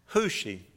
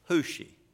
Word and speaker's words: Hushai: Hushi. The last one I shared with you, Hushi. You pronounce it like W-H-O-S-H-I, but it is written H-U-S-H-A-I.